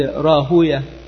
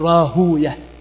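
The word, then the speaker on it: راهويه: والمحدثون يقولون كما ذكر ذلك الحافظ السيوطي في التدريب إن المحدثين يكرهون ويه، يقولون ويه شيطان، فلا ينطقونها هكذا، لا يقولون راهوي، إنما يقول راهوية، ننطقها هكذا لكراهتهم للشياطين ولكراهتنا له أي للشياطين أيضا.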